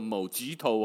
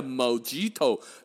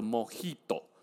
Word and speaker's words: mojito: 那个字哈，M O J I T O 是我们用英文写出来的，它不念作某吉托哦。你哪去酒吧咁点讲？Please, I like a mojito。让直接笑你看，你一颗松巴拉变变松，真卡松松甲嘴巴你妈妈，迄个唔是叫做某吉托，系叫做莫吉托。那你那个发音要对哈，J 不发 G，要发呵。